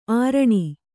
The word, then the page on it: ಅರಣ: Kannada